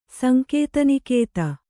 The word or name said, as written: ಸಂಕೇತನಿಕೇತ